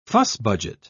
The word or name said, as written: fussbudget